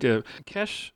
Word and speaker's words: Kesh: We're speaking with Sam Robichaud of the band, and we just heard Kesh the Boat, and I'm not mispronouncing that, by the way. It is K-E-S-H, Kesh the Boat,